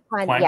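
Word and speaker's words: Kuang: Kwan. Yes. Yeah, Kwan. Kwan, yep. Is that how you say her name? Yeah.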